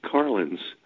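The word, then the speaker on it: Karlins: Hi, my name is Mark Carlins. It's actually a fairly simple name to pronounce. The K is a little bit like a C, and it sounds like a car that you would drive.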